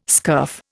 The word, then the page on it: scurf: Indian